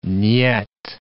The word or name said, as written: nyet